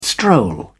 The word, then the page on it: stroll: British English